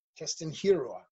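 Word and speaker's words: Keston Hiura: Keston Hirara.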